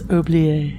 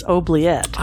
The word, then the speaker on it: oubliette: Unless you're in France, and then it's Oublié. I looked this up. I thought, have we been mispronouncing it the whole episode? But the American pronunciation is oubliette.